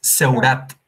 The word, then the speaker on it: Seurat: Son Serrat lo, estás pronunciando como en inglés, Serrat es Seurat, o sea, para que la gente lo pueda buscar en, Google. Se escribe Seurat.